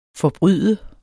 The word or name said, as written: Forbryde